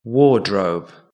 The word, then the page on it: wardrobe: British English